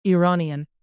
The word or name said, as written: iranian